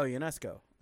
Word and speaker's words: UNESCO: Oh, UNESCO. I'm UNESCO? – Sorry.